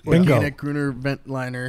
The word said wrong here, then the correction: Veltliner: Am I saying that right, Brian? I don't think I'm saying that right. – Gruner Veltliner. – Veltliner. – But Veltliner works.